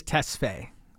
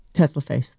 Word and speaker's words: Tesfaye: It's Tesfaye. – Tesfaye. – I mean, that's how it's spelled. – Tesfaye? – F-A-Y-E. – Tesla Face.